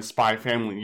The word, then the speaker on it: Spy x Family: Yeah, where they're just called Hunter Hunter and Spy Family. You don't pronounce the cross in it.